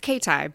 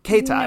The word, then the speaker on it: Katai: It's Katai, but Katai. No worries.